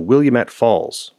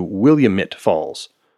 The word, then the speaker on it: Willamette: At the time, I pronounced the name of this water source for this hydroelectric power plant as the Willamette Falls, which is how it's written. Gary, a local in the area, assures me that while this is a common mispronunciation made by people from away, locals pronounce it closer to Willamette Falls.